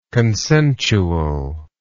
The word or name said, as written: consentual